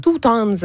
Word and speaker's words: Toutons: Toutons. Ça s'écrit touton, mais on dit un toutons.